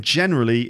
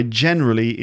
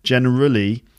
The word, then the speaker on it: generally: So again, generally is on the first syllable too. So the way I generally introduce. So not generally, but generally.